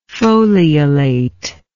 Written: foliolate

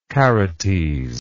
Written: Charites

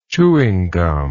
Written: chewing gum

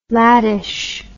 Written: laddish

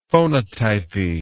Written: phonotypy

